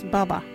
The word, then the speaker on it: baba: Our local Wadjuri word today is Baba. What is Baba? Baba is water. You can use Baba in this sentence. You got any Baba? Or Baba everywhere. Baba is spelt B-A-B-A.